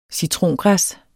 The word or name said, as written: citrongræs